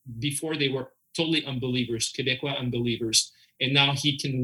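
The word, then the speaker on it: Québécois: Well, in English, I guess it's Quebecers, but Quebecois.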